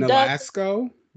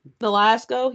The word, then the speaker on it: Nolasco: We have Amari Nolasco. I'm sorry, I butchered his name. Playing Nolasco. – Nolasco, yeah.